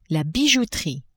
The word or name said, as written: bijouterie